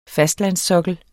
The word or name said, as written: fastlandssokkel